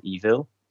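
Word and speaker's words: Eville: So this game's called Evil. And that's how it's pronounced, even though it's spelt with a double L-E.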